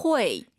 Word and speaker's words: 会: 후이가 아니라 발음 다시 들어볼게요. – 회. – UI 사이에 넣어야 돼요. 회.